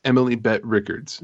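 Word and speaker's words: Emily Bett Rickards: Am I saying that right? – Emily Bette Rickards.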